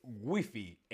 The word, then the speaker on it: wifi: Bien, pues la correcta es wifi. En España decimos wifi.